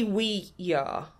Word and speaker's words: Ouija: Because it should be Ouija, because it's yes in French and German.